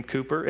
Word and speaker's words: Cowper: William Cooper, no one demonstrated the need for this process of faith more than William Cooper. It's spelt Cowper, but pronounced Cooper.